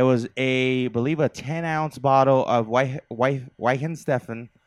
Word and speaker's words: Weihenstephaner: Wiener Steven. – No, it's Weihenstephaner.